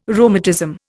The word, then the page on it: rheumatism: Indian